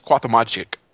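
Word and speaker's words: Cuauhtémoc: It's Cuauhtemoc. That's how you pronounce it.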